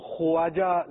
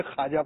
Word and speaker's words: ख्वाजा: वैसे लिखने में तो ख्वाजा लिखा जाएगा, लेकिन पढ़ने में बाव साइलेंट करेंगे, ख्वाजा पढ़ेंगे आप, तो पढ़ने के और हैं अल्फाज और लिखने के अल्फाज और हैं, ये वाव साइलेंट है तो ये पढ़ा नहीं जाएगा। तो ख्वाजा गरीब नवाज रहमतुल्लाहि ताला अलैह,